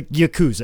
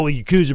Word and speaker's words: yakuza: Like like the the yakuza. Everybody said, oh yakuza brother. No, the yakuza. no, it's, Yakuza. it's yakuza.